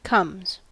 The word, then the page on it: comes: American English